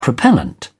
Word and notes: propellant: British English

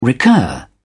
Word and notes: recur: British English

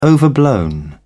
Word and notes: overblown: British English

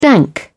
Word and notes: dank: British English

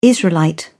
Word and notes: israelite: British English

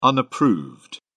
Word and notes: unapproved: British English

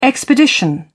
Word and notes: expedition: British English

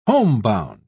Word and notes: homebound: British English

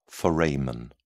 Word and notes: foramen: British English

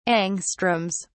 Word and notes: angstroms: British English

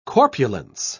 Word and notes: corpulence: British English

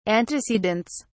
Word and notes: antecedents: British English

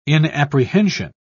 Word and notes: inapprehension: British English

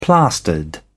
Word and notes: plastered: British English

